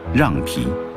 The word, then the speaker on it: 酿皮: 虽然全国各地都叫酿皮，但敦煌人还是固执地坚持古代读音让皮。